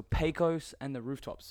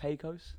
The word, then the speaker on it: Pecos and the Rooftops: All right, so Pecos and the Rooftops, saying that right? – That's correct. – Pecos.